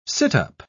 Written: sit-up

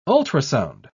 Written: ultrasound

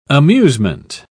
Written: amusement

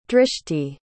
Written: drishti

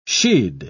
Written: she'd

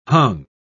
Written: hung

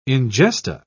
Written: ingesta

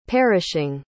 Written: perishing

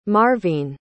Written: marvene

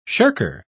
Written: shirker

